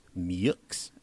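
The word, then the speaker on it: Melton-Meaux: How very American that a guy whose last name is spelled M-E-A-U-X is pronounced Meeks.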